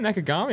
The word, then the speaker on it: Nakagami: and Takaki Nakagami 10th. – Wow. – Oh. Top 10, Nakagami, I didn't know.